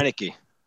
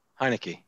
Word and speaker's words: Heinicke: So first, in week 16, we saw Taylor Heinicke, and yeah, I just wanted to give you guys Heinicke. – That's how you say his name? – Heinicke, yeah. – Like Heineken without the N? – Yes.